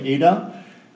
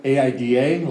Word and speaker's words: Aida: Right, and together we have a 3-year-old girl named Ada. Her name is spelled AIDA. A lot of people say, "Oh, Aida," and then they say, "We didn't know that you love the African musical so much."